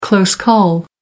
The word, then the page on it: close call: American English